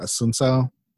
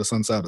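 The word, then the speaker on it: Assunção: I'm not sure exactly how to say it, Asuncao. I believe that's how you pronounce it.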